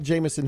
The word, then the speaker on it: Jameson: You can also follow me over on Twitter at Jameson Haygood is the handle on Twitter, and the Facebook page is by the same name.